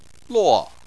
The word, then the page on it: luo: 4